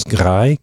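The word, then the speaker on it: Graj: C'est, écoutez, tous les titres, albums, chansons et bandes, c'est illisible, mais après une recherche, je pense qu'on prononce Grai, un peu comme gris, mais ça voudrait dire le cri d'un oiseau ou le chant d'un je sais pas quoi.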